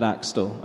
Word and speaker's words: Axtell: So my name is my actual name, which is Ed Axtell, and you spell that with A-X-T-E-L-L.